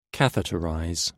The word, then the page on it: catheterize: British English